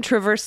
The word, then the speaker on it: Traverse: The correct pronunciation is Traverse City. I, I don't know if this is Traverse. Traverse? Like like there's because there's an apostrophe, so we got a correction to a correction like a pronunciation, but now we're not sure how to pronounce the correction. So Traverse City? I think it's Travers.